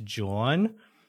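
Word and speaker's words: jawn: I think it's pronounced John, and it's J-A-W-N.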